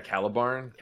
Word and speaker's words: Calibarn: I really like, is it pronounced Caliban? – Oh, the second, yeah, Calibarn. Calibarn is cool.